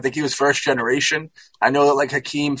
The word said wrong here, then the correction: Joaquin: Phoenix, or I say that wrong, right, Huck? What is it? Joaquin? – Oh, yeah, Joaquin.